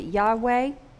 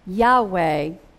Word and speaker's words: Yahweh: I usually pronounce it Yahweh.